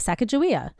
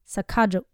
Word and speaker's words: Sacagawea: Yeah, and that the J sound is actually a hard G, and that the you put the emphasis on the second syllable, not the first. So Sacagawea.